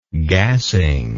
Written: gassing

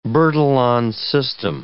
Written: Bertillon system

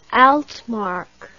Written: altmark

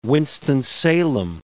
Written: Winston-Salem

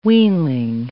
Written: weanling

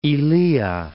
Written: Ilia

